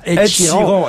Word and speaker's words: Ed Sheeran: En tout cas, vous l'avez bien prononcé, Ed Sheeran, on dit bien Ed Sheeran.